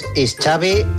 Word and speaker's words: Xavi: Like chocolate, My name is Xavi, like chocolate.